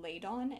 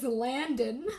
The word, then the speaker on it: Ladon: Did I say it wrong? – No. I've just been... My brain has been reading it as Landon. There's no A.